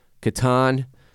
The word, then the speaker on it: Catan: How's it pronounced? Catan. Caton, C-A-T-A-N, the fucking board game that Jared Borslow, the menace, brought up last week.